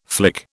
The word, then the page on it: flick: British English